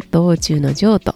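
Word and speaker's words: 動中の静: えっ、ー、と、道中の情という言葉を聞いたことありますでしょうか道中の情ですね。これは動く中の静けさと書いて、動く中の静けさと静けさと書いて道中の情と読みます。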